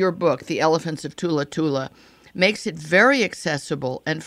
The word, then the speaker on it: Thula Thula: And I think that am I saying it right, Thula Thula, or do you pronounce it with a TH? – No, not Thula Thula is correct.